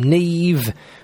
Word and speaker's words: Niamh: The Irish name, Neve, which is spelt nothing like it sounds, N-I-A-M-H, in case you didn't know.